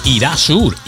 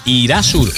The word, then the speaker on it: Erasure: El temazo de esta formación se llamó Irasur, Irasur o Erasure, que es como se escribe.